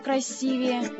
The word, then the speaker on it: красивее: Правильно говорить красивее. Ну, скажем, вы сравниваете один цветок с другим и говорите, цветок жасмина красивее цветка одуванчика. Или, скажем, голубое небо красивее неба пасмурного или хмурого. – В этом слове ударение не меняется, поэтому мы говорим красивый, красивое, красивее.